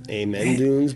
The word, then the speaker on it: Amen Dunes: No, Dunes. Maybe it's Amen. Amen Dunes. But A-M-E-N Dunes.